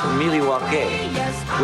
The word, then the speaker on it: Milwaukee: actually is pronounced Miliwake, which is Algonquin for the good land.